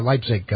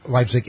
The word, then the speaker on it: Leipzig: Both you and Kyle mispronounced Leipzig, and I'm sure I'm probably still doing it. It should be articulated as Z in pizza and G as H.